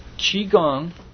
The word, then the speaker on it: Qigong: You keep saying Tiangong. Is that the same as... Is that how that's pronounced? – Qigong.